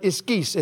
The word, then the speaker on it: ischus: It's the word ischus. It's the word I-S-C-H-U-S, or I-S-K-U-S is the way you'd pronounce it. Ischus. It's really I-S-C-H-U-S, but that's for those of you that get excited about those kind of things.